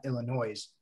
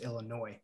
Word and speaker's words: Illinois: Because, but again, you know, Illinois is not Illinois. It's Illinois without the S in the end.